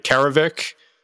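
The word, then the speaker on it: Karevik: And to step in, Tommy, I'm going to butcher his last name, but Karavik. – Yeah, yep, that is correct as far as I know.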